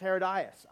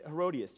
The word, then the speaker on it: Herodias: We have multiple nationalities and we have multiple pronunciations. Tim said, which is the right one? I said, the right one is where you come from. How do you pronounce it? Read. So uh, for, for Tim and our fellow Australians, it's Herodias. I grew up with Herodias.